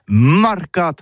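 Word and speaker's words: marcats: Eh bien, disons à tous, mais le mercat de l'Uni ou le mercat des rebelles, mais en gascogne toulousain, c'est prononcé sur les marcates, avec un A marcat.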